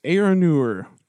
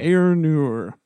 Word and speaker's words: Eärnur: How do you say it? It's Eärnur. – That's how I would say it, yeah. – Eärnur.